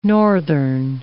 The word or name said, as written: northern